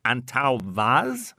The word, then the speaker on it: Antão Vaz: The white is a blend of Arinto and Antão Vaz.